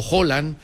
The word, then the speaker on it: Haaland: o Haaland, como dicen ellos que se pronuncia,